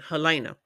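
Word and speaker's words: Helaena: Let me say it correctly, Helena.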